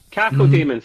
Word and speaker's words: Cacodemon: It's got a floating, and I never know how to pronounce this, one of the demons. Cacodemon. Cacodemon. Thank you.